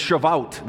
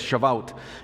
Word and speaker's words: Shavuot: Sorry, it's Shavuot. That's what it is, Shavuot.